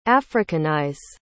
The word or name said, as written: africanize